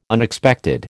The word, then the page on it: Unexpected: American English